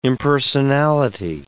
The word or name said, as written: impersonality